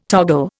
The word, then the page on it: toggle: Indian